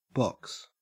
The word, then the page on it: box: Australian English